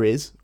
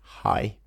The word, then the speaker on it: hay: And of course, Spanish H's are silent, so it's I. You don't say hi, and you don't say hey, especially. So, using I, see if you can understand the following sentences.